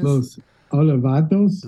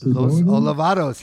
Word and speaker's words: Los Olvidados: All right, so now we have the second track on it is Los, how are we pronouncing this? – Los Olvidados. – Los Olvidados.